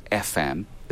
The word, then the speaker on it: ephem: There's basically a standard library you can get called FM. It's spelled E-P-H-E-M. It's for ephemeris.